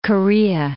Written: Korea

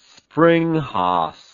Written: springhaas

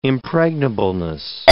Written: impregnableness

